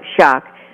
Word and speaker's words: Schock: Now, I got to ask you, Brian, you know, it looks kind of like Scooch, but you pronounce it like Schock.